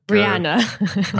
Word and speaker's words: Rihanna: I said it like Rihanna.